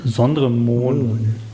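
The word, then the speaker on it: Sondre Moen: Und natürlich hat der Sondre, heißt er nicht, wie spricht man ihn aus? Mohn oder Möhn? – Sondre Mohn. – M-O-E-N.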